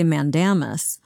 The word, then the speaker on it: mandamus: I say mandamus. And we actually got a letter from a reader, a classicist, who applauded my pronunciation and sort of chewed Preet out for his.